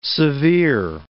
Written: severe

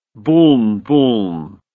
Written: buln-buln

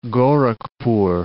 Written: Gorakhpur

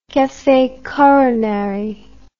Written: cafe coronary

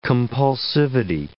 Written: compulsivity

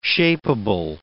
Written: shapeable

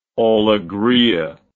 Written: alegria

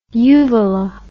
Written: uvala

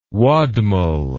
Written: wadmal

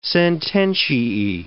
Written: sententiae

